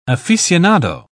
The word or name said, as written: aficionado